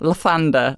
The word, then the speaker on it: Lathander: Lathander rhymes with Amanda.